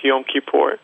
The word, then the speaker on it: Yom Kippur: And 10 days later, after the Jewish New Year, there's the Day of Atonement, correctly pronounced Yom Kippur.